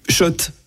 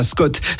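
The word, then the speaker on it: Schott: Oui, Shot. Et pas Scott, ça fait des années qu'on prononce mal la marque.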